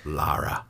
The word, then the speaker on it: Lara: I struggle so much saying Lara. – Lara. – Lara Croft. I always say Laura Croft, but no, yes. It's Lara.